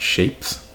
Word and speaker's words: Shvpes: As Lions, and I guess you pronounce it Shapes. – Okay. – S-H-V-P-E-S.